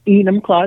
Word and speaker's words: Enumclaw: Enumclaw, it's pronounced.